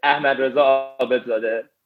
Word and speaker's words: Ahmad Reza Abedzadeh: So the first one is Ahmad Reza Abedzadeh.